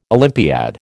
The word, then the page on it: Olympiad: American English